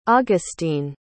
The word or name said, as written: augusteen